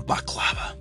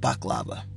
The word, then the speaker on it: baklava: That's going to be one of my favorite things to do, to learn how to make baklava. People say baklava. No, it's called baklava. I learned how to say it the right way.